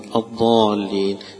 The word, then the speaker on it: الضالين: لكن لا تنطق الضاد ضاء زي ما البعض يقول لك هي هذا مخرجها هذا وتقرأ ولا الضالين يقرأها قريبا من الضاد أو يقرأها كالضاد، هذا ليس بصواب.